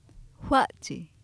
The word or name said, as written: fA7chi